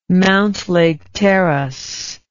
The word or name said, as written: Mountlake Terrace